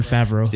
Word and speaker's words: Favreau: John Favreau.